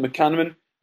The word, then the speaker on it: McManaman: You know, you came up with Richard Dunn having been booked for QPR, Caldwell, McCarthy, McManaman. There you go, I've learned how to say it properly.